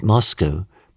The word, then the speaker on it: Moscow: I said, only half-jokingly at the time, that Moscow, pronounced in English, sounds similar to must go, and so I had to go.